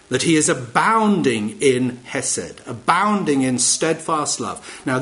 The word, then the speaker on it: hesed: It is the word hesed. That's how we pronounce it in English anyway. It's H-E-S-E-D in its transliteration, all right?